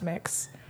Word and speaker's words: Mx: There's mix, which is MX, and it's pronounced mix.